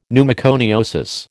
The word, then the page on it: pneumoconiosis: American English